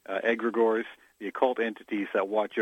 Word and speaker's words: Egregores: Nobody gets it right, so don't worry about it. It's Greek. – It's Greek. – We say egregores, although some say egregores.